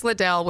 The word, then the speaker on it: Liddell: Is it Little or Liddell? I've tried to research Liddell. – That. Liddell? – Yeah. I think it's Liddell. I'm pretty sure.